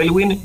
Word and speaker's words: Aylwin: Sí, en Instagram es @ Ignacio Elwin.